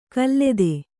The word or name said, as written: ಖಲತಿ